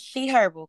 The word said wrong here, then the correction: Her Herbals: Her Herbals.